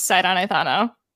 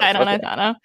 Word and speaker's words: Sidon Ithano: So how do you personally pronounce Sidon Ithano? – The exact same way you just did. – Okay, Sidon Ithano. Sidon Ithano.